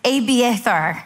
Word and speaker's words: Abiathar: It's Abiathar, son of Ahimelech, bring me the ephod.